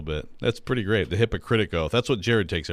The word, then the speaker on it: Hippocratic: Ty says, I did not say hypocritical. I said Hippocratic Oath, Ty. – You did say Hippocratic, so you're good. – Hippocratic. – Yeah, you said it right. – Yeah, Ty thinks I said hypocritic.